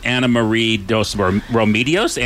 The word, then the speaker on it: Anna Marie Dos Remedios: So, but speaking of getting out of town, our first guest is Anna Marie Dos Remedios.